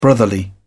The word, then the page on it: brotherly: British English